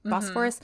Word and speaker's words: Bosphorus: And when they're walking along the Bosphorus, is that how you say it? Bosphorus. Mm-hmm.